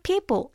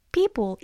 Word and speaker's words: People: People. 얘도 우리가 굉장히 잘하는 단어잖아요. 근데 얘가 단어가 생각보다 쉽지 않아요. 발음하기가. People. 입을 좀 더 많이. 양 옆으로 좀 더 많이. 생각보다 많이 좀 더 찢으셔야 돼요.